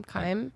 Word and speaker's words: Chaim: How about Kyme Witts? I think I said that right. – I don't know. – C-H-A-I-M, Kyme.